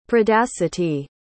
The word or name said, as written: predacity